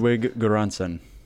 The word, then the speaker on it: Ludwig Göransson: Because it's going to be Ludwig Göransson. – Yes. – Hopefully I said that right. – And yes, you did. You actually nailed it.